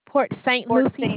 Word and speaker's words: Port St. Lucie: Port St. Lucie, Florida.